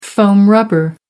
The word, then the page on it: foam rubber: American English